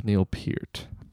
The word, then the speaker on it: Peart: It's pronounced Neil Peart.